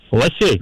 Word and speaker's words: лосей: В данном слове в единственном числе ударение всегда ставится только на корень. А вот во множественном на окончании, поэтому правильно говорить еще раз, как? – Лосей.